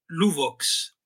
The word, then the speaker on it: Luvox: Pues mira, es muy curioso porque se, claro, se pronunciaría Luvox con una V suave, ¿no? Como en francés o en valenciano.